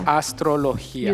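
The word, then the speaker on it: astrología: Thank you so much for explaining how this Astrologia. works. Astrologia. – Astrologia. – Astrologia. – You said it correctly.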